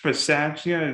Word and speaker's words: Bisaccia: And how about, I don't know if I'm saying his name right, Rich Bisaccia, is that how you say it? – Yep.